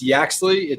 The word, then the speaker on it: Yaxley: It's Yaxley. It's old Yax. English, means cuckoo over the hill.